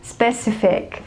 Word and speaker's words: specific: I hear people say something like specific. Yeah, it sounds like it is correct, but it is not correct. It is not stressed on the second syllable, but it is stressed on the third syllable, which is C, okay? And the first two, actually the first one, the S sound, it's the only half sound, so you say it together with the second one and it goes like sp, sp, sp.